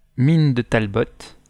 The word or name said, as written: talbot